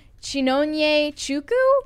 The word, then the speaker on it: Chinonye Chukwu: And the director's name is, I hope I pronounced this right, Chinonye Chukwu? – Yes.